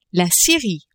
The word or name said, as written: Syrie